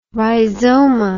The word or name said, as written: rhizoma